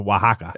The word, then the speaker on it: Oaxaca: Oh, but it's pronounced Oaxaca.